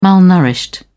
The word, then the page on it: malnourished: British English